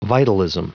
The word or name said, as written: vitalism